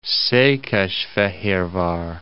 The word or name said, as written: Szekesfehervar